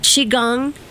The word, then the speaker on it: Qigong: Now, you are a Qigong master.